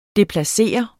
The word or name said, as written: DEPLACERE